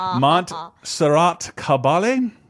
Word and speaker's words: Montserrat Caballé: Montserrat Caballé.